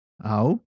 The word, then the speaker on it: au: All right, so let's begin by reviewing the papani helu kahi, and they are au, yeah, au, which is I or me. And remember, the last time on the last video, I said when you pronounce this word, do not add the okina.